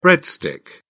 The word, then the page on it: breadstick: British English